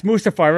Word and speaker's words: Mustafar: Yes, Mustafar.